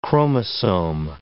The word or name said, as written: chromosome